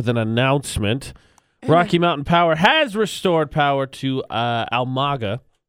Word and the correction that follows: Amalga: Amalga. – Whatever. Almaga. – Amalga. A-M-A-L-G-A.